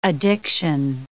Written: addiction

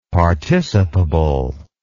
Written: participable

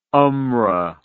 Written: umrah

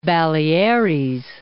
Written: Baleares

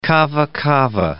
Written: kava kava

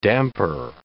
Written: damper

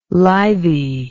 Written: lithy

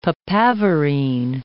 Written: papaverine

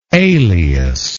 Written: aleus

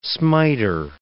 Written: smiter

